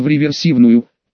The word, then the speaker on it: реверсивную: «Тьфу, твою мать, язык сломаешь, ревет брисерную попадешь», — проговорил он по слогам. «Полудурок ты, Колька. Правильно говорить в реверсивную, Поправил его Петрович и выпрыгнул из кузова.